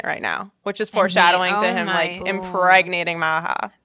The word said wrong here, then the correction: Maja: I think they pronounced it Maya, but it's spelled M A J A.